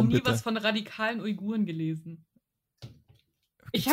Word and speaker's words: Uiguren: Aber wie gesagt, also wenn, wenn ich mich da um eingelesen habe mit den, ich kann das immer noch nicht richtig aussprechen, Uiguren? – Uiguren, ja.